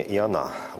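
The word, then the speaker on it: Иона: Да, здесь все-таки с таким с еврейским вариантом произношения «и она», ударение на «а».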